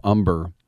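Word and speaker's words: Humber: When you think about King Felix and Matt Cain, and I mentioned Philip Umber with the silent H,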